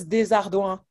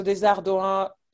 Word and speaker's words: Desardouin: Yes, Desardouin. So Desardouin, like the trick here is like, so the O-U-I-N at the end, O-U sounds like OU, and then I-N is one sound as well, and it sounds like UN.